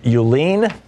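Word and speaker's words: Uihlein: and is being supported by Richard Euline, I believe is how it's pronounced, Richard and Elizabeth Euline.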